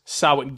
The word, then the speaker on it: Samhain: That is Samhain. – Whoa. – Pronounced Samhain, Gaelic. Gaelic.